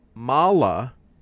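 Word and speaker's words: Mawla: Allah said, Allah is the Mala, it's M-A-W-L-A.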